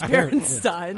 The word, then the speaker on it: Berenstain: Are they Steens or Stains? – I thought it was Berenstain. – I like Berenstain. – It's Berenstain.